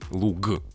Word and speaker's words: луг: Мы произносим «в», а пишем букву «г» вот эта уникальная ситуация, когда мы, э, ладно, там на конце слов, например, пишем букву «г», хотя произносим звук «к». Ну, какой-нибудь там э, лук, который луг.